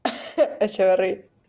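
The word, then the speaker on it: Etcheverry: And we've got Zverev against, you say his name, Echeverry.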